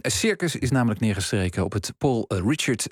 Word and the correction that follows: Ricard: of Richard, hoe spreek je dat uit? – Ricard.